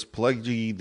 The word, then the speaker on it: Pileggi: Nicholas Pileggi and says, Pileggi? Am I saying that right? – Yeah, Pileggi, I think.